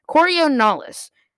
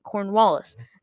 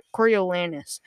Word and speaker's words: Coriolanus: Coriolanus. At first, the entire time, I was pronouncing it Cornwallis. Then I realized there is no W, and it is ridiculous.